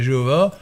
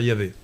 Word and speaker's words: Yahvé: Nous, disons Yahvé. Euh, au 19e siècle, on disait Jéhovah, mais il vaudrait plutôt mieux dire Yahvé.